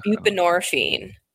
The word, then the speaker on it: buprenorphine: So what I am worried about is pain relief as a long term buprenorphine patient. Am I pronouncing that right? Probably. – I think it's buprenorphine.